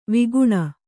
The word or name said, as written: ವಿಗುಣ